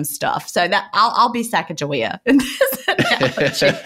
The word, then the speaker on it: Sacagawea: That's the way we pronounced it. I grew up in Virginia and I went to Camp Sacagawea, which was a Girl Scout camp. So that's how we said it. But I think other people pronounce it differently. So if I'm saying it wrong, apologies. – Yeah, the way you're saying it is how I've always known it.